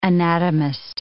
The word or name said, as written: anatomist